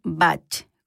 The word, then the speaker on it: Bach: Bueno, les cuento que su correcta pronunciación es Bach, con CH.